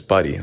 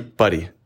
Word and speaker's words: Pari: And then my last name is Buddy. Like, that's how you actually pronounce this, Buddy. – Buddy? – Buddy. So it's like P-A-R-I.